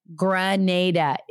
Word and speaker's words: Grenada: Because I was like, is it Granada? Is it Grenada? What is it? And they were like, Grenada. And I've had a few people correct me here in the United States on how I pronounce that. And you know what? Grenada is correct.